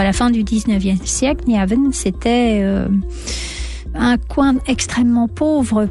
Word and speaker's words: Nyhavn: Je sais que je ne le dis pas correctement. – Oui. – On peut voir de nombreuses maisons aux façades colorées. Alors dites-nous la prononciation correcte de ce quartier mythique. – Nyhavn. Mais vous ne prononcez pas si mal que ça, oui.